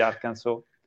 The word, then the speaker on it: Arkansas: In America, come sapete, c'è il Kansas, che si scrive Kansas e si legge Kansas, e poi c'è Arkansas, che però si legge Arkansas.